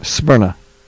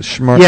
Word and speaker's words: Smyrna: Because S-M-Y... – Smyrna. Smyrna. – Okay. – I think is how they pronounce it. Smyrna. – Yeah, Smyrna, Georgia.